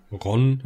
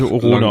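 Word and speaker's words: Rhône: Ron, weiß ich nicht, französischer Fluss. Ich glaube, die, die wird tatsächlich Rhone ausgesprochen.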